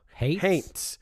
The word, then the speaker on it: haints: It's a creepy little book about a girl in the woods and witches and haints. It's like a gothic fairy tale. – Haints. – H-A-I-N-T-S. Like, rhymes with taints.